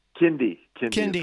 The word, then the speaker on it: Kindy: Kindy.